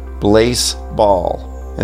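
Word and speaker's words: Blaseball: Yes, I pronounced that right. Blazeball.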